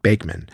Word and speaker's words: Bachman: By the way, although the world would pronounce Bachman as if it were Bachman, the family pronounced it Bakeman, and so did the band when referring to itself.